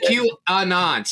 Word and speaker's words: QAnon: How do you say it? QAnon. So you do the A's not silent.